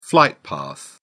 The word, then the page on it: flight path: British English